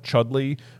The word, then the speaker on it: Chudleigh: I think it is based on similar, and then I think near Otterton is a town called Chudley, which is spelled Chudleigh.